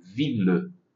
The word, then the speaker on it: ville: Tem uma grande exceção a esse encontro do ILLE, que é a palavra ville, la ville de Paris.